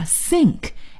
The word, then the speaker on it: sink: 那么这个水槽还有洗碗槽我们就可以叫 sink, S-I-N-K, sink。注意这里呢是 S 的发音，要发 S 的音，也就是这个舌尖不用伸出来，一起再来试一下 sink, sink。